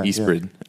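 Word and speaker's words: Ysbryd: Oh, the R before the Y trips me up. – Yeah, I think it's like Yeastbrid. – Okay, yeah, Yeastbrid.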